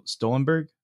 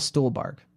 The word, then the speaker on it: Stuhlbarg: And I think she does a great job, as does I never pronounce his name right, but so it's Michael Stuhlbarg. – Michael Stuhlbarg.